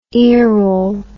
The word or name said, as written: earole